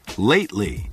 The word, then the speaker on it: Lately: 大家发现没有，在这个单词当中 T 几乎不发音了，千万不能读成 Lately. Lately 应该是 Lately.